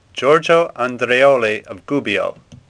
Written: Andreoli

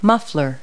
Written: muffler

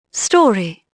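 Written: story

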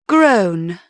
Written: grown

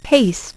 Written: Paste

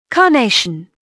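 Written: carnation

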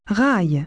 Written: rail

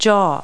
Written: jaw

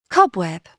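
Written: cobweb

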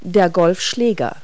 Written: golf club

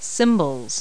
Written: cymbals

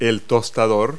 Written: toaster

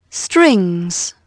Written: strings

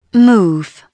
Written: move

